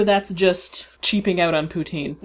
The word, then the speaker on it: poutine: One, poutine, or actually, I think the pronunciation that's closer to it, it's not exact, because I know this is not exactly the right pronunciation, but the closer pronunciation is poutine. I think there's a little slight S sound in the middle there somewhere.